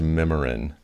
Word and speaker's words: Mimiran: So it is pronounced Mimiran. Yeah. That's right. Great. – It looks like Mimi ran.